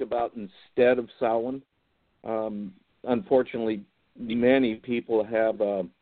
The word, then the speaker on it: Samhain: And if you try to spell it, it'll spell like it's Samhain, but that's Samhain, and that's where the roots of Halloween come from.